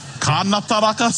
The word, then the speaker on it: kanataraka: Kanataraka, say it properly, Jared, carefully.